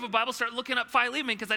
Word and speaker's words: Philemon: 1106, don't be afraid to look up Philemon in the index otherwise, and it's spelled P-H-I-L, like Phil, Philemon.